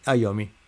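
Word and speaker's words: Iommi: Tony Ayomi me dice gracias por corregir la pronunciación. Así, Tommy Yomi es Ayomi, fenómeno.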